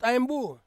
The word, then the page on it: aembu: Ansus